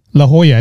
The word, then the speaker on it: La Jolla: La Jolla, I think it's pronounced.